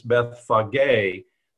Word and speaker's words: Bethphage: And by the way, that is the correct pronunciation. It looks difficult. It's Bethphage, the accent on the last syllable.